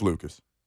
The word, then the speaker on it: Lucas: Last time you said Luca. – He called like Luka Doncic.